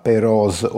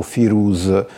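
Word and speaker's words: Peroz: Et l'auteur aborde l'organisation militaire des Perses et à un certain moment il raconte une anecdote sur les rois Péroz ou Firouz selon les différentes prononciations.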